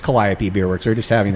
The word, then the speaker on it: Calliope: Um, I named it Calliope Beer Works after the Muse of Music, uh, which is pronounced Calliope. So that's how I say it.